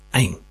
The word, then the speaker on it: ain: That's 3'AIN. It's a name based around an Arabic letter, which in shape is similar to the number 3, but backwards, and phonetically pronounced ain.